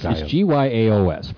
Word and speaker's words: Gyaos: Now, this forces the Japanese military to stop focusing on Gamera and start fighting Gaius. So, Gauss. – Sorry. – Okay. It looks like it should be pronounced. – It's Gyaos for the record.